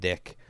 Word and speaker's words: DIC: I think it was a big thing, you know, like, I think they're both early 90s, and that I think DIC is pronounced Dick, but that was a huge thing, and they put out both of these, and...